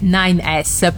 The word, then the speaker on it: 9S: allora, la pronuncia eh, sua è 9S,